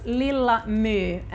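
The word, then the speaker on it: Lilla My: Now, Little Mai we pronounce Lilla Mu, and Mu is definitely, that Tove has also said in an interview, thank God. it's taken from Greek.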